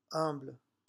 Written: humble

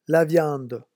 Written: la viande

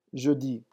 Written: jeudi

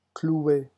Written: clouer

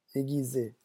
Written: aiguiser

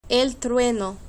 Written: el trueno

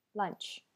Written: lunch

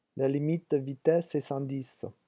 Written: la limite

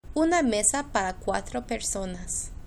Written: una mesa para cuatro personas

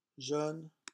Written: jeune

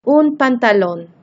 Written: un pantalon

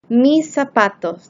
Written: mis zapatos

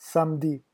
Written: samedi

